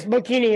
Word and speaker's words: Bocchini: Is that how you say it? Bocchini? – Yes, Bocchini, yeah.